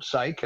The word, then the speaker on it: Saik: And my name is Robert Saik, and the last name is spelled S-A-I-K.